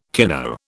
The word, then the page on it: kino: British English